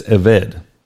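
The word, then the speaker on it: Eved (עבד): But the word slave, in the Hebrew, it's eved.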